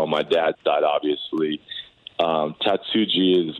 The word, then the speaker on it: Tatsuji: So I want to, it's Tatsuji, that part is the J we pronounce.